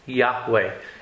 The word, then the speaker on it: Yahweh: The name was pronounced Yahweh.